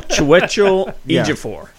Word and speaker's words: Chiwetel Ejiofor: No, Chiwetel Ejiofor.